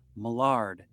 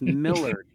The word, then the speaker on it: Millard: Just wondering why you're pronouncing this character's name as Millard. It's Millard. And we're like, what? Really? He's like, yeah, like the president, Millard Fillmore.